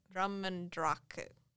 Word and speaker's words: Drumnadrochit: Drumnadrochit. You don't pronounce the hard T.